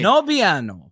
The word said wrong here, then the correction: no bueno: We're both saying it wrong. I just like to share my screen for a second here. – How do you say it? – Listen, I'll have Google. Ready? – Mm-hmm. – No bueno. – Did you hear that? – Yeah, well, that was pretty fucking close, dude.